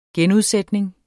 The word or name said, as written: genudsætning